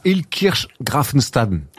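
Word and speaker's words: Illkirch-Graffenstaden: À Hilkirch-Grafenstaden. – Très bien prononcé, hein, parce que c'est pas toujours évident. – Oui, je sais, depuis le temps que j'y joue là-bas, j'ai appris à le prononcer, quoi.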